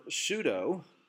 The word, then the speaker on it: pseudo: It's where we get the English word pseudo, a cognate. In English, the P is silent, you know, like psychology. And in Greek, it's pseudos to be exact.